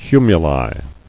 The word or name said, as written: Cumuli